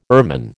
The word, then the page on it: ermine: American English